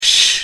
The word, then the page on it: sh: American English